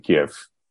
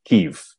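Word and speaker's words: Kyiv: I know a guy in Kiev. Or actually, Kiev. That's how they say it in Ukraine.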